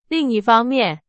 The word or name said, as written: 另一方面